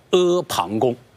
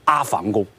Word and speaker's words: 阿房宫: 由于秦朝灭亡以后还没有建造好，还没有给他一个正式的一个美丽的名字，所以天下的老百姓就称呼这个宫殿叫大山旁边的一个宫殿叫阿旁宫啊。这是我对阿旁宫的一个解释，不仅是它的词义啊，而且包括它的读音啊，这是需要大家要了解的。你最好不要读成阿房宫。